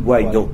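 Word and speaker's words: Guaidó: Am I saying his name right? Guaido or? – Guaido. – Guaido, yes.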